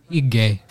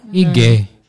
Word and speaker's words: hygge: It looks like, you know the Dutch word hygge. Hygge. It's spelled H-Y-G-G-E.